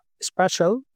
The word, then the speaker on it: Spaitial: Es la palabra spatial, es decir, espacial, pero metiendo AI, metiendo las siglas de Artificial Intelligence en el medio. Es decir, S-P-A-I-T-I-A-L. Spatial.